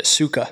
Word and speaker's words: sukkah: Um, in Amos 9, 11 through 12, the Hebrew word for tabernacle is, uh, I hope I'm saying this right, sukkah. Am I saying that right, Matt? I feel like you would know that. You know that, Sukkah? – Okay, yes.